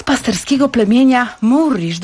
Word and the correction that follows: Mursi: dobrze wymawiam? – Mursi. – A jasne, Mursi.